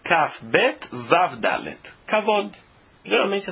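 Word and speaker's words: Kavod (כבוד): Se você escreve Kavod com Vav, a única forma de se ler essa palavra é Kavod.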